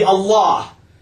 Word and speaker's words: Allah: So instead of Al-Ilah, this disappeared to be Allah.